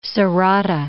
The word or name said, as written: Sorata